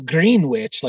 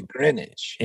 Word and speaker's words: Greenwich: Oh, yeah, because it's Greenwich, like a sandwich, but you say Greenwich. – In England, it's pronounced Greenwich, yeah.